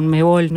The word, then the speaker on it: Conmebol: El que tiene los derechos sobre un contenido, por ejemplo, sobre la Copa Sudamericana con Conmebol, no sé si lo digo bien, ustedes saben.